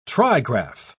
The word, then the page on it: trigraph: British English